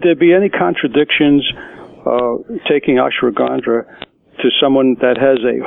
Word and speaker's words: ashwagandha: And one of the things that she had mentioned was something called ashwagandha.